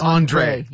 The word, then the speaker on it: Andre: It's Andre. – Yes, Andre.